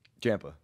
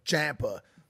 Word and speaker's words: Ciampa: Ciampa. – Ciampa.